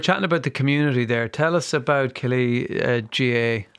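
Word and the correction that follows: Killeagh: Killa, yeah. – Okay. – Yeah. – So it's K-I-L-L-E-A-G-H.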